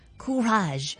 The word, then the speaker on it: courage: 英文有一个词语叫做 courage，可以解作勇气或者系胆量。其实呢一个字都系一个法文字嚟噶。法文我哋会将呢个字读成 courage。